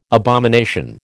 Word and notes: abomination: American English

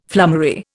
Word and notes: flummery: Indian

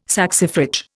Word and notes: saxifrage: Indian

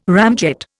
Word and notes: ramjet: Indian

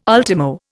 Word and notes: ultimo: Indian

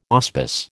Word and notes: auspice: American English

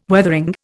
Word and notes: Weathering: Indian

